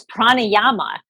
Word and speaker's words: pranayama: Am I saying that right? – Yes, it's pranayama. So if you've ever been,